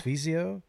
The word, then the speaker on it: Sfizio: Sfizio. The SF. Sfizio. It is an Italian style pilsner from our good friends at Fort Point Brewing.